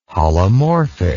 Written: holomorphic

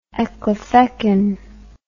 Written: ecclefechan

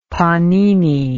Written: pannini

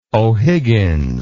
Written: ohiggins

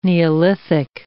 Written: neolithic

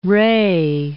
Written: ray

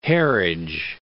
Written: Harwich

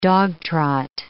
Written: dogtrot

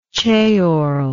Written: ceorlish

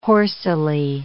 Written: horsily

